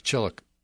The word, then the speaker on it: Chillicothe: That's Chillicothe. See, it's Chillicothe, I think, is the way it's pronounced in Missouri.